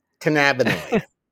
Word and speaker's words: cannabinoid: Another cannabinoid. Am I saying the name right? Can- no, cannabinoid.